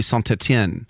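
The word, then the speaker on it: Saint-Étienne: It's like Paris Saint-Germain, because it's a T going to a G. But if you have Etienne, then you pronounce a T, you say Saint-Etienne. So it depends on the vowel, and you pronounce that last.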